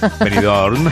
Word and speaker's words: Benidorm: Benidorm, acaba sí, claro. En M, claro, por eso he pronunciado la M para nuestros amigos de Benidorm, que nos escuchan con tanto cariño.